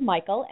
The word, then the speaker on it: Mikal: His name is Michael, and it's spelled a little bit differently. It's M-I-K-A-L, and this is one of my favorite songs from him.